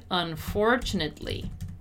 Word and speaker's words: unfortunately: Notice how the stressed syllable is the four. Unfortunately, unfortunately. And also, we don't really pronounce the E. So it's basically something like unfortunately. Something like that, right?